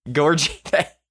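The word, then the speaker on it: Gorgui Dieng: So Gorgie, I'm totally butchering his name. Gorgie Dang. He's Gorgie Dang. Yes. Thank you.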